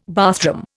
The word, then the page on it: bathroom: Indian